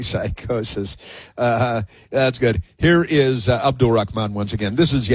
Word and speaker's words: Abdul: We gotta say it like that, man. It's creepy. – Abdul. – That's how it's pronounced. – Holds on to the L a little too long.